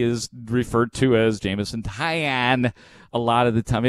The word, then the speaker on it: Taillon: Jameson Taillon, who has been bad for the Cubs, and his name is pronounced Jameson Taillon. But 50% of the people say Taillon, and I just don't understand. I don't get it.